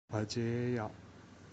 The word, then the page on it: ಅಜೇಯ: Kannada